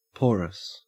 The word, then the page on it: porous: Australian English